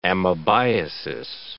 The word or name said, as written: amebiasis